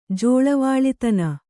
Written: ಜೋಳವಾಳಿತನ

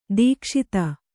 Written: ದಕ್ಷತೆ

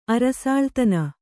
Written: ಅರಸಾಳ್ತನ

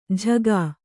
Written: ಝಗಾ

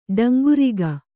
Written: ಡಂಗುರಿಗ

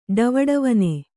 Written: ಡವಡವನೆ

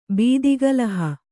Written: ಬೀದಿಗಲಹ

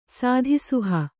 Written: ಸಾಧಿಸುಹ

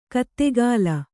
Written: ಗುದಿಗಾಲು

